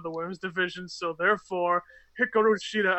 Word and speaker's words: Hikaru Shida: You have to say it like how she said the name. Hikaru Shida! – I honestly don't think is saying it right.